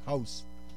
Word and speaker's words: Gous: It's Dutch, but it's spelled G-O-U-S, but pronounced House.